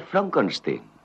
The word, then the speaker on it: Frankenstein: ¿Doctor Frankenstein? Frankenstein. ¿Me toma el pelo? No, se pronuncia Frankenstein.